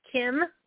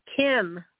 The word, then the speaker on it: Kim: Kim. – I'm sorry. What is your name? – Kim. – Kim. – How do you spell it? – K-I-M.